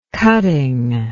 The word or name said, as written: cuttingness